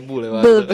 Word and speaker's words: Boulevard: Ah, il pezzo si scrive BLVD. – Ok. Dovrebbe essere Boulevard.